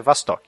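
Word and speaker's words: Vostok (Восток): Vostok, o, o pronúncia russa é Vostok, que basicamente é, é, é uma nave também, uma lata de sardinha, só que é diferente da Mercury porque ela é redonda.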